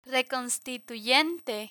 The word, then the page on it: reconstituyente: Spanish